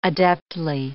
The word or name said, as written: adeptly